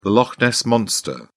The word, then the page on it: loch ness monster: British English